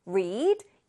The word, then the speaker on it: read: 25번 read, read. read의 과거형은 철자는 똑같아요. 그쵸? 생긴 건 똑같은데 발음이 다르다고 했습니다. read, 읽었다는 read.